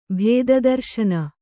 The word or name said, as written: ಭೇದದರ್ಶನ